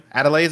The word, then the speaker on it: Adelaide: But this is, like, when he comes back into the spaceship, there's this one moment where Adelaide looks up at him. Adelaide, is that how to say her name? – Mm-hmm.